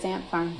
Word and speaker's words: Ant Farm: On the Disney show Ant Farm, which was am I saying that right, or is it A&T Farm? – No, you are saying it correct. It's Ant Farm.